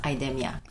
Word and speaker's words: IDEMIA: So the first question out of the gate, is it IDEMIA or is it IDEMIA? I've heard it pronounced both ways, and so I think people need to know, what is it? – So it's pronounced IDEMIA. – IDEMIA.